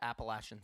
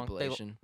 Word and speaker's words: Appalachian: Coast Carolina lost last week to Appalachian State. I said that wrong. – Appalachian. – Sorry, I'm not from Kentucky.